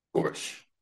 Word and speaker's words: Gorsuch: Gorsuch, am I saying it right? – Gorsuch.